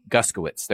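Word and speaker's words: Guskiewicz: Anybody want to help me? Guskowitz. There we go.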